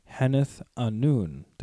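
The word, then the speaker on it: Henneth Annûn: So what really happens, Faramir leads them to the super cool ranger's hideout called Heneth Anun. Did I say that right? – Yes, you did.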